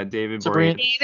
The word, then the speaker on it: Boreanaz: Is that how you pronounce his name? – Yeah, David Boreanaz.